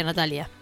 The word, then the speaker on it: Natalia: Las rusas que escuchan a Natalia Oreiro deben ser copadas, porque se pronuncia Natalia.